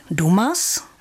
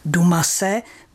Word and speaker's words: Dumas: Píše se Dumas a měli bychom se tedy držet té výslovnosti Dumas, ale to bychom skloňovali podle vzoru předseda.